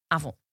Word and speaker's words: Avon: Avon.